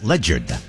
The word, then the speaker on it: Ledyard: It's Ledyard, L-E-D-Y-A-R-D. However, the locals pronounce it Ledyard. For some reason, they stick a J in there.